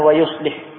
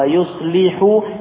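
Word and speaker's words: yuslihu (يصلح): Yahdikumullahu wa yuslihu. Ya, bukan wa yuslih. Yahdikumullahu wa yuslihu balakum bi dhamma.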